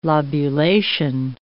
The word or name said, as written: lobulation